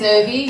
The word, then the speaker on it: Urvi: My name is Urvi. You spell it U-R-V-I.